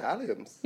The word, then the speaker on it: alliums: Yeah, alliums.